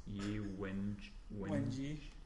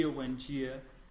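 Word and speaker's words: Wenjie: It was more just like, now I've already forgotten how to pronounce Ye Wenji. – Wenjia.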